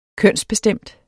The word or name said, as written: kønsbestemt